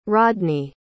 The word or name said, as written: rodney